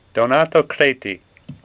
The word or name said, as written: Creti